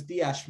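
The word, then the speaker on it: Díaz: I'm going to pronounce his name as Diaz, man. I'm not going to say it with a Z. I'm going to try and pronounce it how the South Americans pronounce it.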